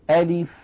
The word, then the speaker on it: ألف: لاحظوني ماذا أقول، ألف يعني أنطق همزة مفتوحة بعدها لام مكسورة بعدها فاء ساكنة.